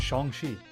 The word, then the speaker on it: Shang-Chi: I've been saying that wrong my entire life, though, Matt, as we said prior to recording, I've never really had cause to say that often at all. But it's Shang-Chi, if we want to be correct about that.